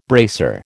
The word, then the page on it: bracer: American English